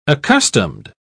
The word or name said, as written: accustomed